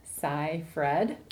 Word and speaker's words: Seyfried: From how it's phonetically spelled out, I feel like it's Seyfried. – I think you're right. – So, like, sci-fi, but, like, Fred at the end.